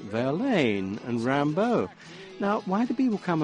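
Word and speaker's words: Rimbaud: Yeah, but knowing about the love of Verlaine and Rambo and rhyming Rambo with "You're going to make me lonesome when you go." I mean, the G-O rhymes with B-A-U-D.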